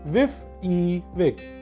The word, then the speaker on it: WYSIWYG: Pronounced WYSIWYG.